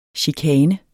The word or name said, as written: chikane